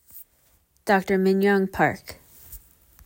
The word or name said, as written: Minyoung Park